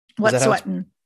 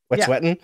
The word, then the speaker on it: Wet'suwet'en: but the Wet'suwet'en, I think. – Wet'suwet'en. Wet'suwet'en.